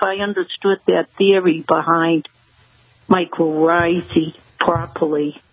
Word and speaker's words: mycorrhizae: the mycorrhizae? – Yes.